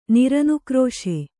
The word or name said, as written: ನಿರನುಕ್ರೋಶ